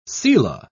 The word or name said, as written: selah